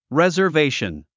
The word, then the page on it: reservation: rèzə r véiʃən; レザベイション